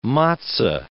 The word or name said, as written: matzoh